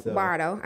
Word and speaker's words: Bordeaux: Okay, Bardo. I know they be coming at me for not saying it right. – you said it right, but you didn't say it Nashville.